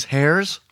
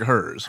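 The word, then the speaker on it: Herr's: Okay, so this is, you pronounce this hairs? Because it looks like hers. – Hers. – H-E-R-R apostrophe S. – I assume maybe it's German with the two R's.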